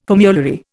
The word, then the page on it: formulary: Indian